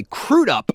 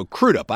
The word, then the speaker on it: Crudup: And by the way, Crudup is how it's said. I said it Crudup one time, and somebody got very angry at me over that. So Crudup, I actually checked it to make sure I was right.